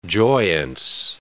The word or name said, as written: joyance